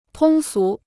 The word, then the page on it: 通俗: tōng sú